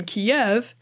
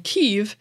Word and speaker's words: Kyiv: In the Russian Kiev, that very same letter sounds like an F. Neither of those come across in most English speakers' pronunciation of the word, which often rhymes with the words sleeve or give, depending on the speaker.